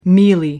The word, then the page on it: mealy: American English